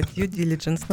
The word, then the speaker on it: due diligence: Вы вот как раз упомянули а, о том, когда мы говорили о видах аудита, вы упомянули due diligence. А, вот давайте поясним, а, зачем нужна такая процедура, что это такое, как она проводится. А теперь правильное произношение. – да due diligence.